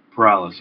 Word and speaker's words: Corrales: Corrales, yeah, that's correct. – Yes, okay. Go ahead. – All right. So, yeah, my name is Frank Corrales.